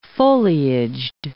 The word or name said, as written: foliaged